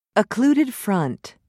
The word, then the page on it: occluded front: British English